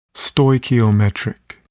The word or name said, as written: stoichiometric